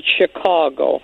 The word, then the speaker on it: Chicago: I've always said a native born always calls it Chicago, so like C-A-W in the middle. Everybody else from other places call it Chicago.